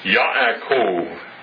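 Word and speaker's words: Yaakov (יעקב): Yaakov. Jacob. You recognize the name? That's the Hebrew pronunciation of Jacob.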